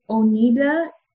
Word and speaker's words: Oneida: And then you leave that kind of context and you come to Oneida. Is that how you say it, Maria? – Yes. – Oh, yes. – So you come to Oneida and you're like in the middle of nowhere.